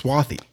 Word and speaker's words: Swati: It's spelled Swati, but I think it's pronounced Swathy. – Swathy. – If I'm understanding that right. Yeah, it's Swathy.